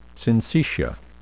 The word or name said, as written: syncytia